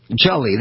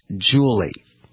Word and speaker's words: Jully: Jully, that's J-U-L-L-Y. And her name had been pronounced, publicly mispronounced, as Julie.